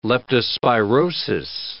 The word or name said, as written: leptospirosis